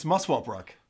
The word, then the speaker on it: Muswell Brook: It's not spelled weird at all. It's Muswell Brook.